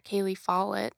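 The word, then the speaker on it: Cailey Follett: It's at Kaylee Follett, yes.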